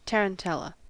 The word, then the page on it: tarantella: American English